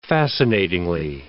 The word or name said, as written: fascinatingly